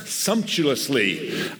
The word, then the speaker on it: sumptuously: Then there was a rich man who was clothed in purple and fine linen, and he feasted how do you pronounce that? Sumptuously.